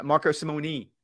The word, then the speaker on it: Marco Simone: Not nay. Knee. Simone. – All right. Knee. Marco Simone. Thank you. Of the four things that matter at Marco Simone, he ranked outside the top 125.